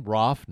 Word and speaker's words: Roof: And yes, as I understand, that's how you pronounce his name. Roof, not Roof. Happy to change that if I learn otherwise.